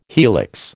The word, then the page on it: helix: American English